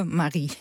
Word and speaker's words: Marie: Een van je hoofdrolspelers is Marie, zeg ik het goed? Mm-hmm. Of is het Marie? – Ja, Marie. – Ja, want het is zonder E aan het eind.